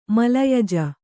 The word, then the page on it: ಮಲಯಜ: malayaja